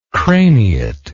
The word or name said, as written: craniate